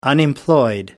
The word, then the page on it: unemployed: American English